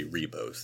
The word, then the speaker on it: Ribos: Now, you did it uh, how it's spelled, but they clearly do say Ribos the entire time. – Oh, okay. Yeah. Sorry. I Re- would assume Rebos.